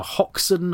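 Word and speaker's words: Hoxne: Well, I will go to 1992, best of all the years in the early 1990s, with the discovery of the Hoxton Hoard.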